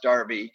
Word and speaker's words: Derby: It's spelled Derby, but pronounced Darby.